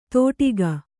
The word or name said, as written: ತೋಟಿಗ